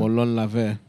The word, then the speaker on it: Molon Labe: I tell them Molon Labe or however you pronounce it. Yeah, Molon Labe.